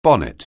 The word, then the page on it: bonnet: anglais